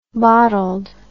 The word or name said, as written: bottled